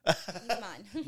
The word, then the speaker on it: Guzman: Guzman.